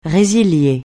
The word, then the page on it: résilier: francais